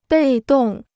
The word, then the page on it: 被动: bèi dòng